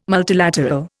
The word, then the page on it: multilateral: Indian